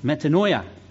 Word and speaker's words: metanoia: And how do we say this one? Metanoia? Am I close? Metanoia. Metanoia.